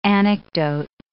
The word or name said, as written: anecdote